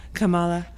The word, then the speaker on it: Kamala: Like, Kamala Harris.